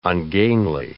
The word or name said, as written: ungainly